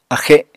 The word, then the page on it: axe: apatani